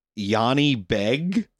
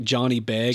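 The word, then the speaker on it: Jani Beg: We had to make sure that we were pronouncing it correctly, but apparently the leader of the Mongol army at this time was a guy named Yanni Beg. – Yeah, but it looks just like Johnny Beg.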